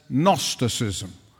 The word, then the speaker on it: Gnosticism: was a heresy known as Gnosticism. You see, the G is silent. The G is silent, but not in Greek.